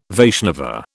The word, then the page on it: Vaishnava: British English